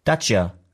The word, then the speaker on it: Dacia: Dacia? Okay, Dacia.